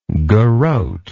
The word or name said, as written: garote